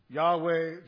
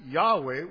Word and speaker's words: Yahweh: The reason other people pronounce it Yahweh, which we're not ultimately sure of, is that Yahweh does and is consistent with the Hebrew pronunciation of the verb to be, which is what the name means.